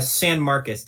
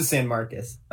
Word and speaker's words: San Marcos: Sorry, I'm super white, so I always pronounce it San Marcos.